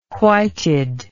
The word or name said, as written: whited